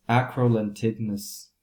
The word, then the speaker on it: acrolentiginous: So it's acrolentiginous melanomas.